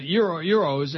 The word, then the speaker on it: gyros: Euros, as in the currency euro. – Yeah. – Somebody says. G-Y-R-O-S, the food is euro. The S is silent.